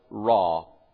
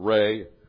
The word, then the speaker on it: Ra: Then you have the darkness, which showed the impotence of their so-called god, Ra, or Re, whichever you want to, pronunciation you want to use, the sun god.